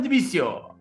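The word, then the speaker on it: Dvicio: La banda española De Vicio, que se pronuncia Divicio, regresa a México a dar dos excelentes shows en la Ciudad de México.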